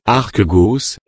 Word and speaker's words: archegos: Dans l'écriture, le mot auteur vient de deux mots grecs, archegos qui se prononce archegos et un autre mot grec aisio, aisio, auteur, un, l'auteur, deux, la cause.